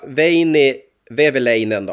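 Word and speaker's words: Veini Vehviläinen: Som målvakt så tog jag Veini Vevelainen då. Var det rätt uttal, Abris, eller? – Ja, det lät jättebra.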